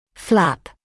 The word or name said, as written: flap